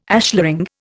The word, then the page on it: ashlaring: Indian